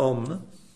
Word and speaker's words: Om: Il mantra Om, ad esempio, si basa su tre suoni fondamentali, A, U, M. Poi nella pronuncia complessiva la Aum viene in qualche modo sintetizzata in Om, ma in realtà si parla proprio di tre suoni ben